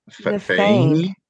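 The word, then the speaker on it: Fáinne: It's F with an A with an apostrophe A, I-N-N-E. – Fane. – And then yeah. – Cladaw is C-H-L-A-D-A-I-G-H.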